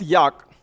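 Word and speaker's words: Jaak: So uh, starting with Yak, his name is pronounced as J-A-K, Yak Panksepp.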